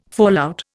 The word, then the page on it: fallout: Indian